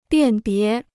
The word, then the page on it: 辨别: biàn bié